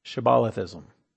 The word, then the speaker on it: Shibbolethism: Shibbolethism rhymes with Catholicism.